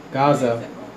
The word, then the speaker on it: Gaza: Gaza.